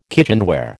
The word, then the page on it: kitchenware: American English